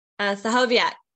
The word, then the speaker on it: Stachowiak: Stachowiak, but very, very close.